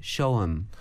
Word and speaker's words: show 'em: 근데 이거를 발음할 때 붙여서 발음을 하기 때문에 show 'em. – 어, 그 영어는 show them인데 show them 안 하고 show 'em.